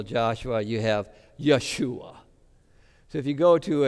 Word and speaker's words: Yeshua: So we, when we praise Jesus, it's really saying Yeshua. That's the way the Hebrews would pronounce it.